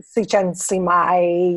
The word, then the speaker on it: Csikszentmihalyi: Mikhail Csikszentmihalyi, yes.